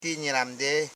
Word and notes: nyiram: nyi.ram

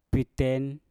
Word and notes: piten: Mpur

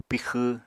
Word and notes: pixw: Apatani

